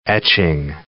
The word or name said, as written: etching